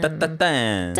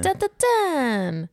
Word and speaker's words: tatin: Tatin. – Tatin. – Tatin. – Ja, så kan man uttala det. Ja. Man kan också säga tarte-tatin som domaren i Masterchef Kanada säger.